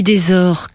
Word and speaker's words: Desors: On dit Desors, comme trésor.